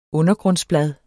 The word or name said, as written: undergrundsblad